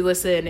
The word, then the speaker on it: LYSN: But basically, Listen is one of SM's many little conglomerate apps. And it's spelled LYSN.